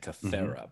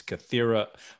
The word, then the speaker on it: Kythera: And then Kes kind of mispronounces it a little bit by saying Kythera, but it's Kythera from Tuvok's mouth.